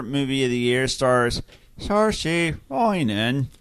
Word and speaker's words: Saoirse: and the robot told us it was pronounced Saoirse Ronan.